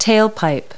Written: tailpipe

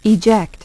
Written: eject

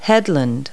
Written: headland

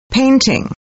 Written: PAINTING

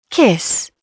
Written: kiss

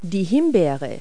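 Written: raspberry

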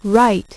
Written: write

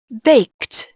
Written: baked

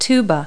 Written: tuba

